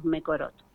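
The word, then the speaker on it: Mekorot: Ese es el nombre, digamos, en inglés, no sé si lo pronuncié bien, pero nosotros comúnmente le decimos Mecorot, con K.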